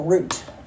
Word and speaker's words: Ruth: While she was there, her two sons also married Moab women, and one of them was named Orpah, the other one was named Ruth, or Root in the Jewish pronunciation.